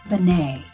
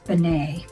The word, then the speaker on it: Benai: So this week I need to apologize to a Reform temple in Westchester, New York, because I called them Temple Benai, and that is not correct. A listener in California, thank you, Carol, taught me that it is Temple Benai. B with that little hyphen thing, N-A-I, is pronounced Benai.